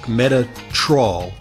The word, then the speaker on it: Metatrol: So it's spelled Meta, M-E-T-A, like the new name for Facebook, Metatrol, M-A-T-A-T-R-O-L.